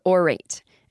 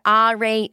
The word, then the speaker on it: Aurate: So the company is called Aurate and it's spelled A-U-R-A-T-E, but they told me it is pronounced Aurate. So don't give me your Aurate or whatever your fucking accent is. Aurate. That's what they want you to say. A-U-R-A-T-E.